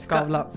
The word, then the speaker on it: Skavlan: Skavlan.